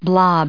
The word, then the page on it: blob: noun